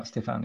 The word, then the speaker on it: Stéphane: Just Stéphane, even though the E on the end, it's still pronounced Stéphane. – Yeah, Stefan. Yeah.